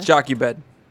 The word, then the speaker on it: Jochebed: Jochebed.